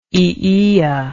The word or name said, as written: aeaea